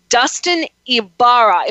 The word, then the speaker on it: Dustin Ybarra: I'm going to say Dustin Ybarra.